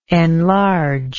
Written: enlargingly

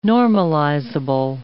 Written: normalizable

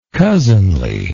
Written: cousinly

